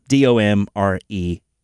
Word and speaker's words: Domremy: So Domremy, it's got an emphasis on the E, so you look it up. It's Domremy.